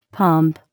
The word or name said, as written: pump